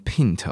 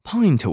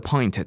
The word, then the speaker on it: pint: 好，这个字不是念成 pint 哦，这个字念成 pint，pint。啊，pint 就是一个。一杯的那个单位 pint，我后来去查了一下下，在英国的 pint 大概是五百六十八 cc，OK，然后在美国的 pint 大概是四百七十三 cc。